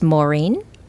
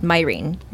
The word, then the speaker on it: Mairin: But yeah, it's a true Irish pronunciation of my name is Mairin. – Mairin, okay. – Yeah, but my parents are not Irish, and so they pronounce it Mairin.